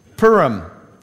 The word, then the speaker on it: Purim: Purim.